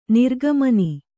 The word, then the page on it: ನೆರಿಕೆಮನೆ: nerike mane